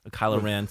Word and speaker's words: Kylo Ren: But when we actually have that scene where, Rey gets into that special little, trophy room for Rylo Ken and everything. Kylo Ren, sorry.